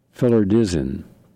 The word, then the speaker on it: phloridzin: Filler, P-H-L-O-R-I-D-Z-I-N. Filaridzin.